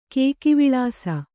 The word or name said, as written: ಕೇಕಿವಿಳಾಸ